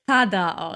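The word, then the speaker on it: Zara: Sarah, ich habe das wirklich mit mehreren Videos habe ich das recherchiert, spricht man wirklich Thada aus. T-H-A-D-A. Thada. Ist aus Spanien, spricht man so aus.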